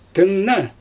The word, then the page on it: tɯnna: Xyzyl